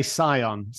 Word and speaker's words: Scion: It's like Scion or Skion or something like that. – I would say Scion Capital is his hedge fund.